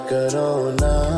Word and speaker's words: करो ना: करो ना एहतियात करो सो प्यार करो ना which is not me mispronouncing Corona, but it's K-A-R-O-N-A.